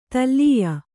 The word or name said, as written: ತಲ್ಲೀಯ